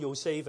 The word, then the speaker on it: Yosef: So if you look it up, and with gender usage, etc., and then meaning and history, and I'll just highlight it, from Yosef, the Latin form of the Greek also sang Yosef, which was from the Hebrew name, actually it's pronounced Yosef as well, meaning he will add.